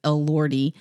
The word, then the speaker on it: Elordi: I don't know, never knew how to say his last name, but I think it's Elordi. – That sounds right to me.